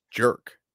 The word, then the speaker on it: Jurick: I think it's just pronounced Jurik.